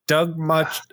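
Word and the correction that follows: Moench: I butchered his name again. We're going to have to send him another edible arrangement. Doug Monench, who was writing Batman at the time, wrote the Catwoman story in there with Ed Hennigan on art.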